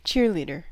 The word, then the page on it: cheerleader: American English